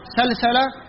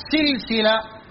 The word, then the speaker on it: سلسلة: تنطق سلسلة بفتح السين الأولى وسكون اللام وفتح السين الثانية وتنطق سلسلة بكسر السينين الأولى والثانية.